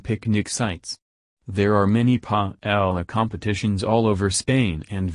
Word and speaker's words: paella: And finally, if you don't already know and you want to impress your friends, paella is pronounced paella with the E as in bet.